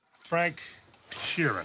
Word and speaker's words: Sheeran: Frank Sheeran. Am I saying that right? – Yeah, you said it right.